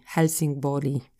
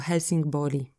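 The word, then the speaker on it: Helsingborg: Olborg, albo jeszcze tak podpowiem tu wam, że to G czytamy takie I jakby Olbori, Helsingbori się powinno czytać. – A, tak, okay. nie czyta się G, tylko Helsingbori.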